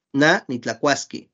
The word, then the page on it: na nitlakuaskí: Náhuatl